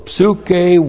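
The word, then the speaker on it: psuche (ψυχή): The accusative plural of psuche with the definite article. P-S-U-C-H long E. Psuche, sometimes soul, sometimes your life.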